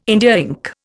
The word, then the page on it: India ink: Indian